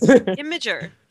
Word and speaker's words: Imgur: Imgur.